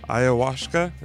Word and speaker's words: Ayahuasca: Ayahuasca, I think is how you say that. Is that correct? – Yeah. – Oh, I did it right.